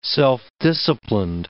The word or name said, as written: self-disciplined